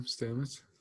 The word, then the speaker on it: Stamets: Paul Stamos, yeah. – Yeah, just on or however you pronounce his name, Stamets.